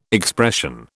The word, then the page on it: expression: British English